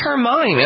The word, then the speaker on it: Hermine: Hermine? I think it's Hermine. I mean, I'm tempted to say Hermione because Hermione? Of yes. Harry Potter, but it's not spelled yes. the same way.